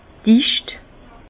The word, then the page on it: dicht: Bas-Rhin; Herrlisheim